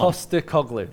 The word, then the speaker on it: Postecoglou: Come Postecoglou. There, there you go. You did um, it first time.